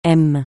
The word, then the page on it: m: francais